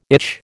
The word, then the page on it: itch: American English